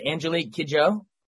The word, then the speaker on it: Angelique Kidjo: So I guess first of all, we have, and make sure I say this right, is it Angelique Kidjo? – Yeah.